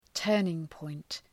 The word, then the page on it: turning point: ‘tɜ:rnıŋpɔınt